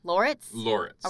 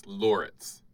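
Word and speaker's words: Lauritz: Laritz. – Are we sure? – Yeah, like Laura It's Laritz.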